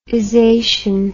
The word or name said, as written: isation